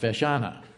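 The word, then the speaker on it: feiseanna: The plural is FESHANA, pronounced FESHANA.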